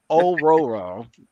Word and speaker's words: Aurora: to give you her origin of Oreo or Aurora. I can never say her name. Aurora. Storm. – Aurora is how I always, yeah.